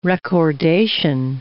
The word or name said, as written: recordation